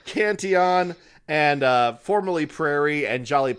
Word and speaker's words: Cantillon: Cantillon. – I always want to say Cantillion, and I know that's wrong.